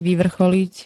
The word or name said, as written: vyvrcholiť